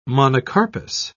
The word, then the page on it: monocarpous: British English